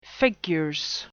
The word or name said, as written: figures